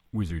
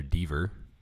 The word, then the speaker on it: Whizzer Deaver: This episode features the return of the beloved Whizzer Deaver.